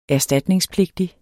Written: erstatningspligtig

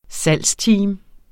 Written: salgsteam

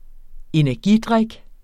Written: energidrik

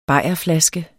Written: bajerflaske

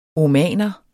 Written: omaner

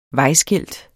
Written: vejskilt

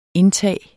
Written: indtag

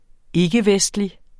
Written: ikkevestlig

